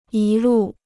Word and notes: 一路: yī lù

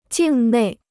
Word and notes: 境内: jìng nèi

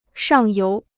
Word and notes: 上游: shàng yóu

